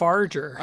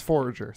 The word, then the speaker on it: Forager: You said Farger. – I did say Forager. I said it wrong. It's Forager. Sorry.